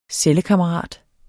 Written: cellekammerat

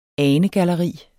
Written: anegalleri

